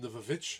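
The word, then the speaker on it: The VVitch: Every time I say it like that, I feel like I need to give it that accent. I can't say The Vavitch. I have to be like, The Vavitch!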